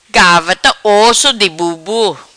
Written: Gavarta Osso Di Bur Bur

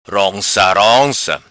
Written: Ronca Ronca

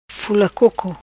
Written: Fula Coco

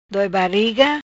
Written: Doi Bariga

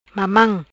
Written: Mamam

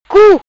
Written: Cu

